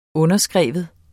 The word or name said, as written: underskrive